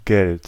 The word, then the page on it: Geld: IPA: /ˈɡɛlt/; German